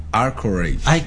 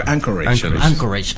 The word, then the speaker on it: Anchorage: Creo que se pronuncia Anchorage. – Bueno, es que mi letra ya. – Anchorage, Alaska.